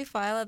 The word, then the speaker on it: Feile: I want to say Phyla, that's what Google Translate told me, but in case I'm mispronouncing it, it's spelled F-E-I-L-E if you want to check the artist out.